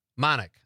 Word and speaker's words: Manick: Manick. I thought so. Rachel Manick. Rhymes with Bionic.